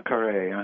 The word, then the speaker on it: Poincaré: Poincaré, uh-huh.